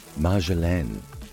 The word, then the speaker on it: Marjolaine: Marjoram in French is pronounced Marjolaine and spelled M-A-R-J-O-L-A-I-N-E.